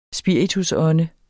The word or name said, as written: spiritusånde